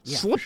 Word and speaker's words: Sleipnir: I'm pronouncing that absolutely correct. – Yeah. – Sleipnir. – Sure. That's the way I'd say it.